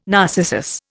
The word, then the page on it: narcissus: Indian